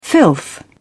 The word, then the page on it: filth: British English